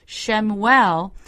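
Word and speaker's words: Shemuel (שמואל): Shemuel is how you say Samuel in Hebrew.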